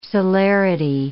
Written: celerity

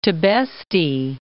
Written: Tibesti Mountains

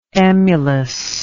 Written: emulousness

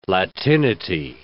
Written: latinity

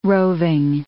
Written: roving

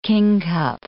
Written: kingcup

